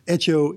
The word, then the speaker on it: Hecho: Echo is H-E-C-H-O. The opening H is silent.